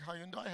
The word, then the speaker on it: Hyundai: Do you have Hyundais here? Okay. Do you say it like Hyundai? How do you say it here? Hyundai. Yeah, it's good. You guys are normal.